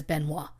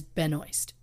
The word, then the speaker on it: Benoist: Now look, the Canadian in me wants to say her last name is Benoit. – Yeah. – But I went and watched YouTube videos of her saying her name so I could pronounce it the way she wants it pronounced, and she says Benoist.